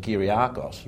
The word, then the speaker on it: Kyriakos: Actually, I did pronounce it the other day, and I pronounced both names, Kokkinakis and Kyriakos, right? – Yes.